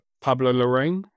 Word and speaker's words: Pablo Larraín: I think I probably butcher his name right here, but Pablo Larraín.